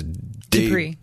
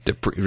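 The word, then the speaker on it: Du Prez: Music by John, how do I say this? – Dupree. – How do you say it? – Dupree.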